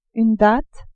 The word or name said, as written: datte